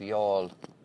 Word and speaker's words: Youghal: Yall, which is obviously should be spelled Y-A-W-L, Y-O-U-G-H-A-L. I mean, how on earth they got the pronunciation of Y-O-U-G-H-A-L, or the spelling of that.